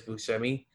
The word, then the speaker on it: Buscemi: I grew up saying Buscemi. Like, yeah. So that's how he says it, but like, it's Italian, and the Italian way to say it is Buscemi.